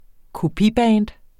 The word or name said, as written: kopiband